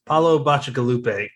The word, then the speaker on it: Paolo Bacigalupi: He writes sci-fi, and I had to look up how to pronounce it, but I finally nailed it down. Paolo Bacigalupi. And it doesn't look like that, at least to me.